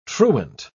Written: truant

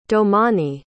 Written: domani

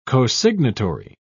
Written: cosignatory

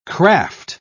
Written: kraft

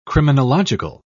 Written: criminological

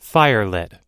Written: firelit

